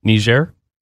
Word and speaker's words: Niger: Niger.